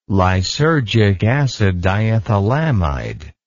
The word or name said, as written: lysergic acid diethylamide